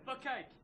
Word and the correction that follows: bukkake: It's pronounced bukkake, but we will...